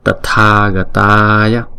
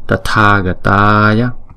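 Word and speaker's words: Tathāgatāya: Còn chữ TH là tờ. Tathagataya Tathagataya. Hai chữ A màu đỏ có dấu gạch ngang trên đầu thì chúng ta đọc kéo dài hai nhịp.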